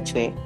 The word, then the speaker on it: Choi: kalau ibu warteg kan waktu awal si Hei pengen daftar ke kelasnya Mister Choi Choi itu bacanya Choi itu uh, kan agak kaget karena dikira anaknya baik-baik aja belajar sendiri tapi ternyata dia hmm.